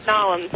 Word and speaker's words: New Orleans: CNN radio correspondent Amanda Moyer, who's in New Orleans or Narlands. Is it Narlands? Is that kind of how they say it? – Pretty much, yes, Narlands.